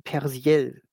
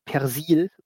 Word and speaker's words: Persiel: Regie hat Martin Persiel geführt oder Martin Persil. Ähm, ganz, ganz, sicher wird das so ausgesprochen. Also P-E-R-S-I-E-L. Äh, mhm. Und er ist Deutscher, kommt aus Berlin. Also ich würde wahrscheinlich ist es wirklich Persil.